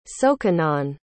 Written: sokanon

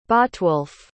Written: Botwolf

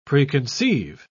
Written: preconceive

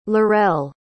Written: lorelle

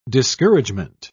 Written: discouragement

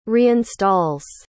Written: reinstalls

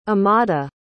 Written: amada

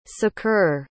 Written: saqr

